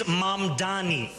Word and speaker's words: Mamdani: And furthermore, the name is Mamdani, M-A-M-D-A-N-I. You should learn how to say it because we got to get it right.